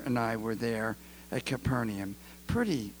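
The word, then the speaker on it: Kfar Nahum: Jesus lived with his leading disciple, Simon Peter, and Simon Peter's mother-in-law, in a little home in Capernaum. I don't know how they say it in Hebrew. Kafar Nahum, the village of the prophet Nahum, and Capernaum. And it's right beside the lake, the Sea of Galilee.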